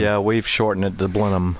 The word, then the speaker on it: Blenheim: Hey, you guys are well pronouncing it Blenheim because... – Blenheim? – Say Blenheim. – Yeah. Right, Blenheim. Because that's... – In Gloucestershire. – Because that's lazy talk. That's southern talk.